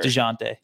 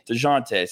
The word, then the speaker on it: Dejounte: DeJounte. – DeJounte. See, I mean, it's the silent J that gets you every time.